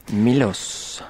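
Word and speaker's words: Miloš: Milos.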